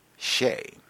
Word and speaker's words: Che: My younger girl is named Chegiana and goes by Che, pronounced She.